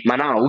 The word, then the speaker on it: Manau: che erano i Manau, se non erro. – Sì, che credo si pronunci, esatto. – Manau. – Sì, Manau, effettivamente, io sono pure laureato in lingua, figure faccio.